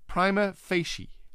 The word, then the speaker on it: prima facie: It's not prima facie either. The correct pronunciation is prima facie. Prima facie. Prima meaning first word prima, second word facie.